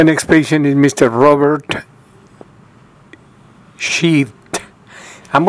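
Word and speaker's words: Scheidt: Sheet.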